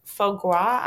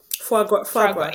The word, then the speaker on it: foie gras: But I had kind of a similar moment too when I was on a traveling and they had brought foie gras. I'm totally butchering that. Mm-hmm. Yeah. – Foie gras.